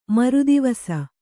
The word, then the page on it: ಮರುದಿವಸ: maru divasa